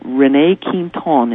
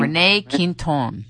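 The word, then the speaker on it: René Quinton: So Rene Quinton is how you pronounce this man's name. – Rene Quinton. – Rene Quinton.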